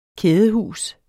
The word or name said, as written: kædehus